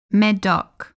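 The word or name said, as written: Médoc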